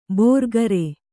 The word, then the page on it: ಭೋರ್ಗರೆ: bhōrgare